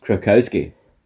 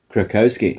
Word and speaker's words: Krakowski: Krakowski. – Right, I'll do Jane. – Krakowski. – There you go. – See. One more. – Jane. – Krakowski.